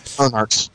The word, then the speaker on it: Earnhardts: Did I say it right? Earnhardts. – Yep, Earnhardts.